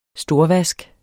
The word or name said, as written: storvask